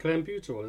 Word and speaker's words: clenbuterol: Go on, you try and pronounce that. – Clenbuterol, isn't it? – Oh, thank you.